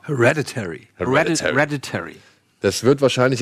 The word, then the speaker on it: Hereditary: Hereditary. – Hereditary.